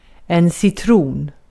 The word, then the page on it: citron: /sɪtruːn/